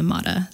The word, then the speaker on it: Mater: So I want it to sound like terracotta, Terra Mater. I mean, not purposely, but to rhyme with terracotta. I like the Mata sound.